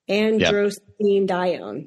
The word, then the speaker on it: Androstenedione: A, endostrandione. I don't think I said that right. B, melatonin. C, aldosterone. D, cortisol. E, angiotensin II. Androstenedione. Yep. There you go.